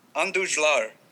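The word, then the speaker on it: Andújar: I was Todd Frazier, because they've got Miguel Andujar. I don't know how to say his last name, but Andujar.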